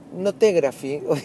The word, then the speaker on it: Notegraphy: que se escribe Note, Gra, Fi, y Me río porque ya me mandaron un montón de mensajes diciendo, pedazo de idiota que sos pronunciando en inglés. Bueno, Note Graphy, ¿sí? Eh, note G-R-A-P-H-Y.